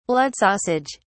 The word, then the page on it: blood sausage: British English